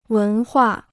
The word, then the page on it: 文化: wén huà